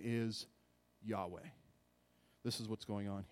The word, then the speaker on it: Yahweh: That is the tetragrammaton, Y-H-W-H. In Hebrew, Yod, He, Vav, He, Yod, He, Vav, He. Y-H-W-H, we say Yahweh. That's how we pronounce it.